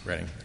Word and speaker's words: Reading: Reading, okay. It's spelled Reading, but it's Reading.